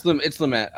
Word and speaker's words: Lumet: And also I thought it was Lumet. I've been saying that wrong my entire life. – Yeah, it's Lumet.